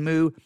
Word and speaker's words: Moo: The first book, A Theology of Paul and His Letters, The Gift of the New Realm in Christ, by Douglas, and his last name is spelled M-O-O. I'm going to say Douglas Moo.